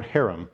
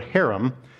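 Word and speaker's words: herem: The word harem, we would have a hard H sound like in the word like Loch Ness monster.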